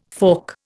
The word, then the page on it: fork: Indian